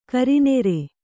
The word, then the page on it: ಕರಿನೀರು: karinīru